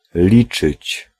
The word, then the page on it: compter: France; [kɔ̃.te]